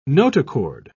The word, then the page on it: notochord: British English